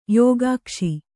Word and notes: ಯೋಗಾಕ್ಷಿ: yōgākṣi

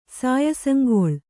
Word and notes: ಸಾಯಸಂಗೊಳ್: Kannada

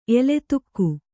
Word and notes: ಎಲತ್ತಿಕೆ: elattike

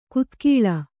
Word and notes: ಕುದಿಗೊಳ್ಳು: Kannada

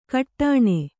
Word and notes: ಘಟ್ಟಣ: kannada

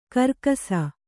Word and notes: ಖರ್ಗಸ: khargasa

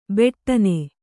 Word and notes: ಬಡಿನ: kannada; baḍina

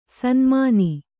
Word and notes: ಸನ್ಮಾನಿ: sanmāni

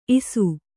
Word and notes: ಇಷ: iṣa

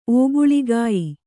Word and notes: ಒಬ್ಬುಳಿಗೆಯ್: obbuḷigey

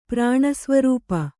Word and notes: ಪ್ರಾಣಸ್ವರೂಪೆ: kannada

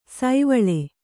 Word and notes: ಸೀವಳಿ: sīvaḷi

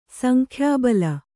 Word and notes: ಸಂಖ್ಯಾಬಲ: Kannada

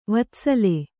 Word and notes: ವತ್ಸಲ: vatsala

